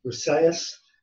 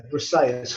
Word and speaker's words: Briseis: And it tells a story of the pronunciation I always get wrong, but it's Briseis, Briseis, who is the prize of honour that Achilles receives once he's sacked, like, one of the cities next to Troy.